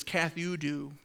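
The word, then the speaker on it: kathudu (καθεύδω): The way you pronounce it is kathudu.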